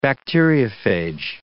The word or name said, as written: bacteriophage